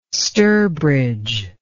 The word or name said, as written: sturbridge